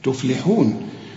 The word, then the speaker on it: تفلحون: هنا كل الكلمة أو الكلمة دي والكلمة الأخرى مرققين، لكن اللام هنا تفلي مرققة ومكسورة، ما تقولش تفلحون تفلولو، لا تفلحون.